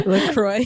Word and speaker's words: LaCroix: You're popping my lacrosse cherry. What is it called? – LaCroix. – LaCroix. – LaCroix cherry. – LaCroix. That's what you're supposed to pronounce it as. LaCroix.